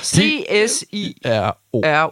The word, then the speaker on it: CSIRO: C0S, C-S-I-R-O.